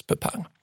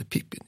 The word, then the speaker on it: Pippin: Pepin däremot finns bara kvar som någon sorts historiserande fransk namnform, alltså vänjer sig många vid att tro att uttalas Pepin. Ända fram till Sagan om ringen då Tolkien ger namnet en ny renässans och då börjar vi kalla det Pippin igen.